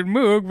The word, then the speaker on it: Moog: In the U.S., they pronounce it Moog, but yeah.